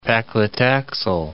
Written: paclitaxel

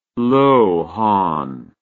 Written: lohan

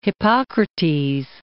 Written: Hippocrates

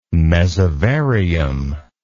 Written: mesovarium

